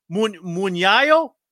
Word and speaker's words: mugnaio: Now I'm going to totally say the name wrong. Mugnaio? – Yeah, you got it.